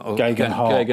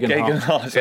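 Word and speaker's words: Geoghegan Hart: I can never pronounce his second name. I always call it Teo van den Garde. – Gegenhardt. – Gegenhardt. – Yeah, there you go.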